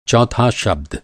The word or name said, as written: चौथा